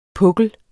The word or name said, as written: PUKLEN